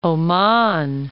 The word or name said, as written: Trucial Oman